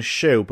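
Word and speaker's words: Schuh: Skoohoo. Apparently, this shop is known as Shoe, but it's not spelled like that.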